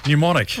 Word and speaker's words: mnemonic: Mnemonic. – Right. – It's a silent M.